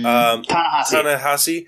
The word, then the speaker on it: Ta-Nehisi: I mean, like, otherwise, yeah, of course you, like, if I don't, the author, I can't pronounce that name, Ta-Nehisi something? – Ta-Nehisi. – Ta-Nehisi,